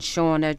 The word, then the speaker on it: Seana: Or O-D-A-I-G-H yeah. – for anyone listening. Yeah. And Shauna, just as the name says, Sean A with S-E-A-N-A.